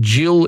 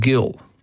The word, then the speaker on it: Gil: Well, to be fair, Gil, I know a couple of Gils, and one is a Gil and one is a Jill, and they both spell it with a G. So there's a choice just about whether it's a hard or a soft G.